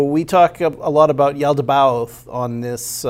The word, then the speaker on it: Yaldabaoth: I pronounce it Yaldabaoth.